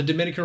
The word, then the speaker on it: Dominica: it's not the dominican republic it's dominica that third syllable gets the accent right exactly.